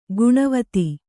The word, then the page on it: ಗಿಣಿವೋದು: giṇivōdu